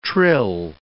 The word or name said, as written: trill